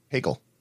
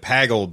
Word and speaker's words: Peggle: How do you say it? – Peggle. – Okay. He said, I felt like he said Paggle before or whatever, but it was funny.